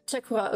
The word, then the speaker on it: Txakurra: Chakura, ok. Non, basque, et du coup, le X se prononce che.